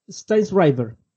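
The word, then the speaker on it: Sztajnszrajber: Es como un trabalengua, Jorge, pero según Wikipedia informa que se pronuncia Steinsriver.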